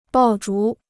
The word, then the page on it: 爆竹: bào zhú